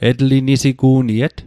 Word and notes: etlinisigu'niet: Mi'gmaq

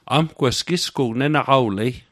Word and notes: nenaqawlet: Mi'gmaq

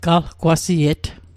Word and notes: qalqwasiet: Mi'gmaq

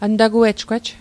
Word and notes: antaguejgwej: an'takuejkwej; Mi'gmaq

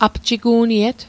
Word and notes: apjigu'niet: apjiku'niet

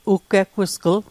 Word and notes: uggeggusgl: Mi'gmaq

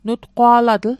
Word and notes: nutqwa'latl: Mi'gmaq

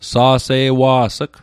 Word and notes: sa'se'wa's'g: Mi'gmaq

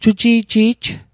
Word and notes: juji'ji'j: Mi'gmaq

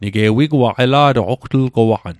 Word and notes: ela'toq: Mi'gmaq

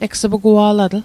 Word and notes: egs'pugua'latl: Mi'gmaq

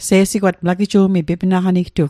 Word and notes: se'sigwatg: Mi'gmaq

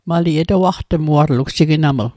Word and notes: etawaqtmuatl: Mi'gmaq

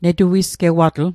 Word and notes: netuisgewatl: Mi'gmaq

